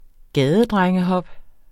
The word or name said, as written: gadedrengehop